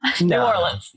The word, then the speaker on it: New Orleans: Did I say that right? – No. – Say it. Give it to me. Go ahead. – New Orleans. – Where are you from? – New Orleans.